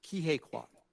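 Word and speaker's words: Ke Huy Quan: Was Kihei Kwan.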